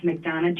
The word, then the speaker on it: McDonough: And McDonough, is it pronounced McDonough, Georgia? – Yes, McDonough, Georgia.